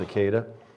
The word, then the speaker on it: Akedah: Another title is the binding of Isaac, which in Hebrew is the word Akedah, A-K-E-D-A, or I've heard it pronounced Akedah, but I think it's Akedah, and sometimes there's an H on the end of that.